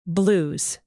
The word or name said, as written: blues